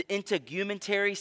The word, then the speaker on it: integumentary: You have the cardiovascular system, the digestive system, the endocrine system, the excretory system, the immune system, the integumentary system. Did I say that right? Thank you. I got a thumbs up from a doctor.